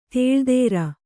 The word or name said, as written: ದಿಲ್ದಾರ